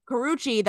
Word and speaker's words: Karrueche: Karrueche, that's right.